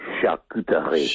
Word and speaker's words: charcuterie: How do you say it? – Oh, it's fun. Just have to embrace it. Charcuterie. – Charcuterie. – roll your mouth around it.